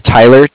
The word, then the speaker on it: Tyler: My name is Tyler, T-Y-L-E-R.